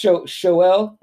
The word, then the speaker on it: Xolo: his name is spelled like this, his real name. XOLO. So it's pronounced Shoel, and his last name is Mariduena.